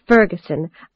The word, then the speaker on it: Ferguson: Ah, my name's Ferguson. I called you last week. – Sorry, can you spell that, please? – F-E-R-G-U-S-O-N.